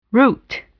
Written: route

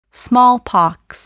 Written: smallpox

